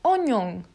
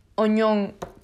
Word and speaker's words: oignon: Bueno, se escribe oignon, pero se, pronuncia. Se me ha cortado porque le he dado como aquí al Google Translator a que lo pronuncie. Ha, pronunciado algo así como oñón.